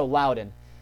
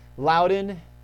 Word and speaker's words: Loudoun: And I did have to use I got on YouTube and was able to find somebody pronouncing it. So I'm pretty confident, because I think there's a place in Virginia called Loudoun, Virginia. But this native Scot was pronouncing it's Loudoun Hill, where there's supposed to be a fort there. Anyway, so Loudoun. Loudoun, the Campbells of Loudoun, that was a significant branch of the Campbells.